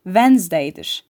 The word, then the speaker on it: Wednesday: Sessiz D harfi için en iyi örnek ise Wednesday'dir.